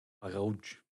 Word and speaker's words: Hagauj: C-I-R-C-A-S-S-I-A-N. – Yeah, or just six letters. H-A-G-A-U-J. – And how do you say it? – Hagauj. – Hagauj. H-A-G-A-U-J.